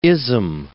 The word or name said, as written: ism